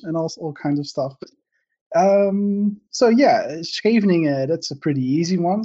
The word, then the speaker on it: Scheveningen: So for me, as a Finn reading Dutch, I can read this as a Scheveningen, and I'm fairly certain it's not pronounced so that somebody from the Netherlands would say, oh, you sounds like a fluent Dutch now. So please introduce yourself and make sure to tell us how to pronounce this word. – Well, it's called Scheveningen.